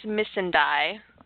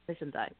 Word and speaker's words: Missandei: I found it very interesting the conversations that he has between Grey Worm and how do I say her name? Missandei? – I think it's Miss and I. – Missandei, yeah.